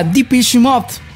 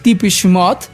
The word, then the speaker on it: Depeche Mode: Se trata de la agrupación británica Depeche Mode, o Depeche Mode, o Depeche Mode, como lo quieran decir. Muchos sitios en internet sugieren que es un vocablo en francés, o sea, que se debe pronunciar este nombre en francés, Depeche Mode.